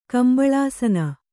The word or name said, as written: ಕಂಬಳಾಸನ